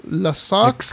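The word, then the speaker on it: Lascaux: Hay una caverna en Francia que se llama Las Caux, que se debe pronunciar algo así como Las Sox, Excaux. – Las Sox o algo así.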